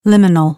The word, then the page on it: liminal: American English